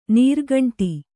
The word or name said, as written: ನೀರ್ಗುಂಡಿ